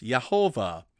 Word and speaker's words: Yehovah: You can do it with two syllables and say Yahweh, or with three syllables, Yehovah.